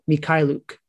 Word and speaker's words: Mykhailiuk: I actually said his name wrong earlier. Svi Mykhailiuk. That's what it is. That's how you say it. Mykhailiuk.